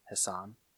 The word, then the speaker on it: Hassan: Hassan, a Middle East terrorist. That is how you pronounce his name, right? Hassan? – Yes.